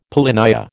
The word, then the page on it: polynya: American English